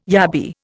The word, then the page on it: yabby: Indian